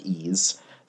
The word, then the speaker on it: Ys: Okay, so that one took a little while to get over here, but do appreciate that they give you they give you a pronunciation for Ys, a thing that no one knew how to pronounce. in America for years and years, as far as I could tell from the fact that it came up every time there was an East game talked about in a magazine, like, oh, you're supposed to pronounce this. But yeah, here they lay it out. It's supposed to be pronounced like ease.